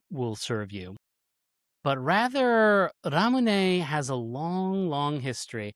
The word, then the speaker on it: Ramune: Yes, it turns out that Ramune is a Japanese pronunciation of lemonade.